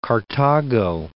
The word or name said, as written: Carthago